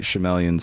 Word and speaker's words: Shyamalan: Night Shyamalan's Signs.